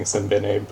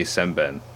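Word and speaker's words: Sembène: Him a couple of years ago, and that, because I made the same mistake of saying Simbene, but I think I was wrong, so... Yeah. I think is how they pronounce it. – There is like an underground cameo in Kempti Thiaroye. They during the roll call they say Semben. So I was like, that is how you pronounce it.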